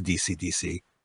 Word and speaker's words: Deacy: His last name is spelled D-E-A-C-Y. – D-E-A-C-Y. – DC. – DC. E-A is E sound, we were told.